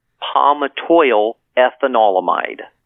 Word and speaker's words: palmitoyl ethanolamide: Yes, this is the new ingredient in town, and its nickname is PEA, and it's short for palmitoyl ethanolamide. – Okay. – Palmitoyl ethanolamide,